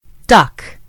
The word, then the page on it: duck: American English